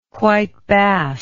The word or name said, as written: white bass